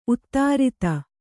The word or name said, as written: ಉತ್ತರಾಧಿ